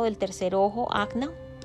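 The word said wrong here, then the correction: Ajna: Se pronuncia Agia. Dios mío, yo pronunciándolo acna.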